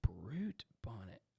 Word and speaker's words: Brute Bonnet: I'm out on Blue Bonnet, or what's his name? can you spell it? Can you spell it for me? – Brute. Space Bonnet. – Brute Bonnet.